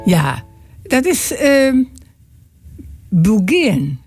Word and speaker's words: beguine: En je spelt het B-E-G-U-I-N-E. De Bulgarian. Zo spreek je het uit.